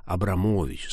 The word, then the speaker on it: Абрамович: Понятно, что на самом деле его фамилия была Абрамович с ударением на «о».